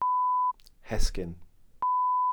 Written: hesgyn

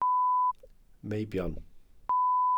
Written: meibion

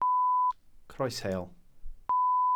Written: croesheol

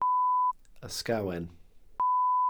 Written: ysgawen

